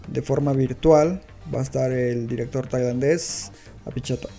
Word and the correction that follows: Apichatpong: Ah, se me fue la pronunciación correcta, es Apichatpong.